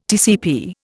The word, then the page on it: TCP: Indian